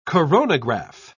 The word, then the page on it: coronograph: British English